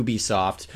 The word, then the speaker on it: Ubisoft: So it's U, like Y-O-U, Ubisoft. – Not Ubisoft. – Not Ubisoft.